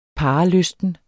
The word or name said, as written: parrelysten